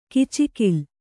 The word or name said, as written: ಕೂಚುಗೋಲು